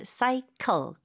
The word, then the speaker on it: cycle: 영어로도 cycle. 근데 이음절로 발음해 주세요.